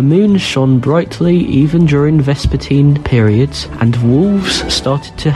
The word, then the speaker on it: vespertine: This week's word is vespertine, also pronounced vespertine. It is spelled V-E-S-P-E-R-T-I-N-E.